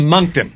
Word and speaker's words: Moncton: Think of the poor people in Moncton when that happens. – No, it's Moncton.